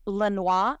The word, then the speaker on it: Lanois: Now, I think he pronounces his name Lanois. At least I saw a documentary where he pronounced it that way.